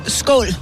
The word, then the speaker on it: skål: Hein yeah, and in Danish, in, it's skål. Skål. Yeah. – Skol. Skol. Skol.